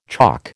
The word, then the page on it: choc: American English